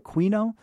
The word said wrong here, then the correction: quinoa: I always mispronounce that. – Quinoa. Yeah, quinoa.